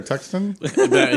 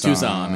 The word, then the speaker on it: Tucson: Like Tucson or Tuxton? – He goes, no, it's Tucson. It's just a funny way of pronouncing it, Tarjay.